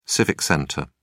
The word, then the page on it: civic center: British English